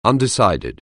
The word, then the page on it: undecided: anglais